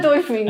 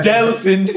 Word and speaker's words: Delfín: Is it, you sure it's not up, dolphin? Please. No, it's not dolphin. – And dolphin. – I pronounced it for us. – Yeah, he's not. – Delphin.